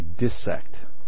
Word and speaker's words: dissect: It's actually dissect. Dissect. Short I. Go look it up. Here's another little interesting thing. E-R-R.